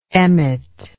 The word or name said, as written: Emmett